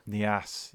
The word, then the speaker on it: Niasse: Niasse.